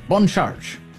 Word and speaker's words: Bon Charge: Bon Charge. That's the way you say it. Little French pronunciation.